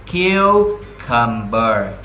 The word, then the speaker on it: cucumber: Ở đây nè, giờ yêu diêu, thì nối vào là khờ yêu kiêu, nhấn âm đầu tiên. Ok, âm này mình sẽ nhấn ha. Còn những âm còn lại chúng ta sẽ xuống nè. Xuống nè, được chưa? Thì cái chữ này mình đọc là cucumber, cucumber, cucumber, cucumber.